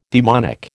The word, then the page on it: daemonic: American English